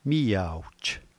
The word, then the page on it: miawj: slow